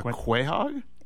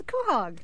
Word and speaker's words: quahog: A quahog? – It is. It's a quahog. – Quahog, sorry. – Nice. – Quahog, according to Family Guy, which is where I take all my pronunciation cues.